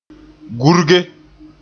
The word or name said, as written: gurge